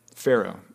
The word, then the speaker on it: Pharaoh: Rhymes with Shmero, starts with a PH. Pharaoh, yeah. Pharaoh, right?